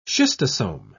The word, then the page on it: schistosome: British English